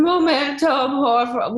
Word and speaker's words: momento: Momento, with the Italian accent. – And I could not say it. And everyone on set was like, it's un momento. And you just see me, un momento.